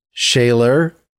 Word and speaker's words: Scheler: So Scheler, you know, that's the sort of German pronunciation. Different than Schiller, that's a different person.